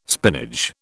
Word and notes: spinach: British English

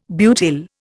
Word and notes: butyl: Indian

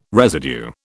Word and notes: residue: British English

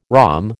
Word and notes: rhomb: American English

